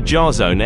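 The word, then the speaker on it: Jahzone: Go to Jarzone M. I'll spell it J-A-H-Z-O-N-E-M. That's it. His name is Jarzone.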